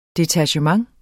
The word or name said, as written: DETACHEMENTERNES